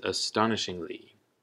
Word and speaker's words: astonishingly: And our last word is astonishingly, astonishingly, with the emphasis on the second syllable, the ston, ston, astonishingly, which is also an adverb.